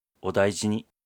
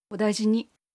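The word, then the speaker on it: اودایجینی (お大事に): اودایجینی. – اودایجینی. – اودایجینی.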